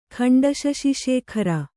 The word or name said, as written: ಖಂಡಶಶಿಶೇಖರ